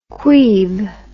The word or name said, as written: queeve